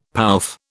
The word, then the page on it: pouffe: British English